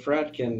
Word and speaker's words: Fratkin: That's my name, Stuart Fratkin.